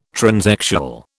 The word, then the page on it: transexual: British English